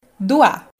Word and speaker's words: Duá: que é uma palavra que se pronuncia assim: Duá.